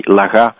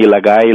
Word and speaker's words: Laguae: You know, it's actually Laga. But people call me Lagai, Lagae, Lagai. So, you know, whatever works for them is good for me.